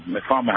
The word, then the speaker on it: metformin: Metformin, how do you say that? – Metformin, yes.